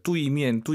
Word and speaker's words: 对面: UI 사이에 에 넣어야죠. 뚜이미엔, 뚜이미엔. 선생님, 알아듣죠? – 能听懂. – 네, 안 예뻐요. 다시 한 번. – 对面. – 뚜에이, 뚜이미엔. 빠르게 발음하시면 뚜이로 들리긴 하죠. 뚜에이, 정확히 발음하셔야 됩니다.